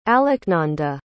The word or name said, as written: alaknanda